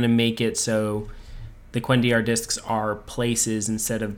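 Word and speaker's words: cuendillar: Yeah, the Kalendor. Or not Kalendor. The Quendiar. – Quendiar, thank you.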